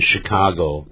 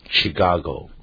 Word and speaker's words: Chicago: Yes、并不是表示他们没有去过那边，但是因为大家都这么念，所以使得有些地名有两种念法，像芝加哥有人念成 Chicago、但也有人念成呢 Chicago 对 Chicago、这样念法都有的啊。